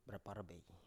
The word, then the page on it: braparbey: Biak